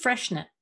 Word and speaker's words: Freixenet: It's spelled F-R-E-I-X-E-N-E-T. And the X makes you kind of confused. And it's actually just freshenet or freshenet.